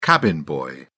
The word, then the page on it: cabin boy: British English